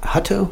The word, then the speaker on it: Hutto: H-U-T-T-O. How would you say that? Hutto or Hutto? – Hutto. – Hutto. Joe Hutto.